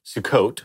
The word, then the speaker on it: Sukkot: And so the Festival of Tabernacles, or simply called Sukkot, am I saying that right? Mm-hmm. Yeah.